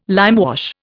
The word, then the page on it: limewash: Indian